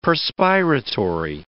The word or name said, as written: perspiratory